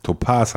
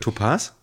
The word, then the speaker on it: Topaz: Topaz heißt das eigentlich. – Topaz? – Ja, nicht Topaz. Wieso egal. Nicht Topaz? – So spricht man das aus. Du sagst ja auch Modus und nicht Modus.